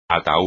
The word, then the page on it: ataúd: espagnol